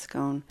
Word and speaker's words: Scone: It looks like school, but it's pronounced scone, which no one would guess on.